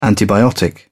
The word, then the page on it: antibiotic: British English